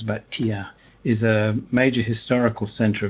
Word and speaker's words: Thiers: It's manufactured in an interesting city in central France called Thiers, that's how you pronounce it.